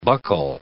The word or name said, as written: buckle